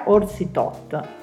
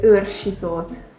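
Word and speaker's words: Orsi Toth: Oggi abbiamo, si parla di beach volley e non solo, in compagnia con due atlete della nazionale italiana, Marta Menegatti e Vittoria Orsi Tot. Il cognome di Vittoria in realtà non è proprio questo, non si pronuncia così. Come si pronuncia? – Sarebbe Urshitot.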